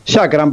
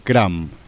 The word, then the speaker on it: Shacram: En la actualidad, Chakram, ¿así lo pronuncio bien o...? – Chakram, perfecto. Sí, sí. – Claro, cual. Porque lleva SH... – Kram. – Kram, exactamente.